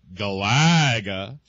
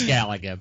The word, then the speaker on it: Galaga: The way I've always squared it away in my head is that the Aussies pronounce it Galaga in honor of Alf Stewart's catchphrase, "You great Galah," as in the rose-breasted cockatoo. – No idea. – And the rest of the world pronounces it correctly.